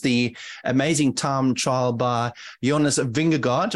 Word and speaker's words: Jonas Vingegaard: I looked up the correct pronunciation, as Jonas would indeed say it himself, and it is Jonas Vingegaard. So that is the correct pronunciation if you're doing it the right way.